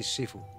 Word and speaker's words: Sifu: Profesor, bueno, maestro más bien, Shisho. ¿Lo he dicho bien? – No, soy Sifu.